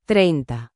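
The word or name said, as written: treinta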